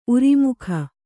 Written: ಊರ್ಮಿಕೆ

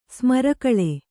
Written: ಸ್ಮರಕಳೆ

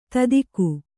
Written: ದಾಧಿಕ

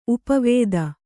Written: ಉಪವಿಧಿ

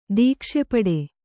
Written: ದೀಕ್ಷೆಪಡೆ

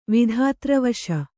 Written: ವಿಧಾತ್ರವಶ

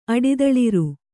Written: ಅಡಿದಳಿರ್

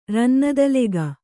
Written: ರನ್ನದಲೆಗ

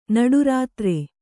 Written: ನಡುರಾತ್ರೆ

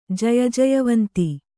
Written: ಜಯಜಯವಂತಿ

